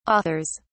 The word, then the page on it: authors: British English